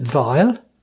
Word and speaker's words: dwile: For example, a floor cloth in Norfolk is a dwile. Pure Dutch, except they pronounce it vile.